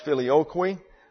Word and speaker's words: filioque: Here's the word, F-I-L-I-O-Q-U-E. It is pronounced filioque. The Q-U-E in Latin gets a Q-W sound, qui. So we have filioque.